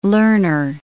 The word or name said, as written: Lerner